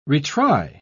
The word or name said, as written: retry